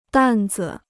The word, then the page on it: 担子: dàn zi